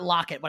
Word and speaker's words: Lockett: the receiver for Seattle whose last name rhymes with Sprocket. I actually said that on the sports betting minutes. – Because I can't say Lockett.